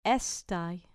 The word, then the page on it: estai: grec